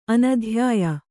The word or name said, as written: ಅನಧ್ಯಾಯ